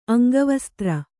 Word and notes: ಅಂಗವಸ್ತ್ರ: aŋgavastra

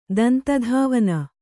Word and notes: ದಂತಧಾವನ: Kannada

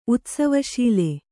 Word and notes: ಉತ್ಸವಶೀಲೆ: utsavaśile

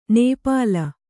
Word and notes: ನಿಪ್ಪಲು: nippalu

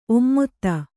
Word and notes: ಒಮ್ಮೊತ್ತ: Kannada

